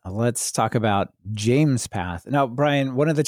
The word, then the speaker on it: JMESPath: But this one is called James Path. And I know because it says JMESPATH, pronounced James Path,